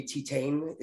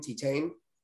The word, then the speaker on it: Titane: Have you seen the French movie Titane? It's spelled like Titan, but with an E at the end, Titane.